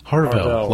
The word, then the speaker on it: Harvell: Harvell, like barbell, only with an H okay. And a V.